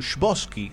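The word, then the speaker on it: Chbosky: Eh, dirigida por Stephen Bosky, puede ser, está bien pronunciado muchachos porque tiene un Shbosky, Shbosky, como lo pronuncias vos, es como lo vamos a pronunciar nosotros durante el bloque. Si nos sale. Bueno, Stephen Shbosky.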